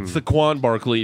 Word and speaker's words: Saquon Barkley: Saquon Barkley, if you don't know how to say his name.